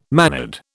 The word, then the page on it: mannered: British English